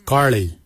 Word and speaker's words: giilyi: Darb is spelled D-H-A-A-B. It is a traditional knife, a darb. For boomerang we say garli. Garli is the traditional name for boomerang. Garli is spelled G-I-I-L-Y-I, garli. For the traditional stone axe we say goj. Gotch. Gotch is spelled G-O-O-D-J.